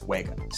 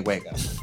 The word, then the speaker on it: Wega: That's the one that it kind of looks like Vega, but with two V's. And Sony's always like, no, it's Wega. Say Wega.